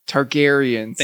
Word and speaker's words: Targaryens: Targaryens.